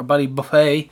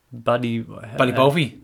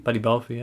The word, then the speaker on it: Ballybofey: and 3-2 on Ballybofey last Friday, or Ballybuffet. – Mm-hmm. – Depends on what part of Ireland you're from, the pronunciation.